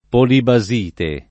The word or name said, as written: polibasite